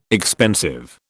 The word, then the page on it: expensive: British English